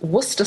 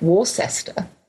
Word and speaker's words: Worcester: And Leon Perrins is called Worcester sauce, although it reads like Worcester. It is pronounced Worcester.